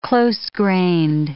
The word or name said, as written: close-grained